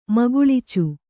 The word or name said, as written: ಮುಗುಳ್ಚು